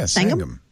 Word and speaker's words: Sangam: I don't know how you pronounce this exactly. Sangam? I would guess. – Yeah, Sangam. Yeah.